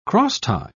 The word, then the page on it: crosstie: British English